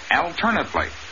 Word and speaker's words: alternately: In the adverb form of the same word, the accent is on the second syllable, alternately, but the A is still short.